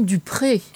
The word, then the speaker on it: Dupree: Alors, ça s'écrit Dupree. Mais en réalité, c'est tout simplement Dupré.